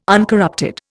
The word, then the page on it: uncorrupted: Indian